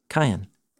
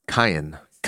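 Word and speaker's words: Cain (קין): That's how you say his name in Hebrew. Cain. – Cain.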